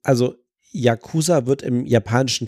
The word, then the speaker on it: Yakuza: Ähm, Yakuza kommt ein bisschen mehr an das Japanische dran, aber eigentlich sagen die Japaner Yakuza. Kein großer Unterschied zwischen den drei Silben, aber das ist eigentlich die Aussprache.